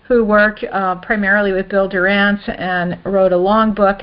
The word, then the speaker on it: Dorrance: And yes, and we say Durant. – Okay. – That's how we pronounce it.